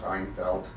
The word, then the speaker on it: Einfeldt: Einfeld. My last name is spelled E-I-N-F-E-L-D-T.